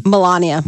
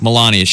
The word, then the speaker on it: Melania: Melania. – Melania.